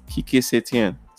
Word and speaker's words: Kike Setién: Kike Setién.